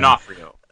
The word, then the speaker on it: D'Onofrio: It's kind of a middle ground of, you know, particularly when we get here, as you were saying, with Vincent D'Onofrio. D'Onofrio? You know, I'm not going to be able to say his name. – D'Onofrio.